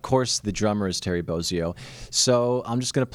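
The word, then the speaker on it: Bozzio: Is it Bozio or Bozio? What do you say? – I think it's Bozio.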